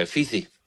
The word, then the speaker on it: Fithi: F-I-T-H-I. – F-I-H-I, Fihi. Fihi? – F-I-T-H-I. – Oh, T-H-I, Fifi.